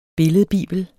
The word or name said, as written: billedbibel